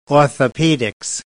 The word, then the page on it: orthopedics: British English